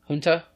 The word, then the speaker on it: junta: Junta, you said? – Junta's how it's pronounced, yeah.